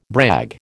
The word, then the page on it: brag: American English